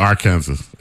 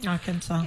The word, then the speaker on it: Arkansas: It's just like K-A-N-S-A-S is what? K-A-N-S-A-S. – Right. Right. – Kansas, right? – Yeah, mm-hmm. yeah. – But you put an A and an R in front of that. What is it? – You get Arkansas, and then you pronounce Arkansas. It, and then you pronounce it differently.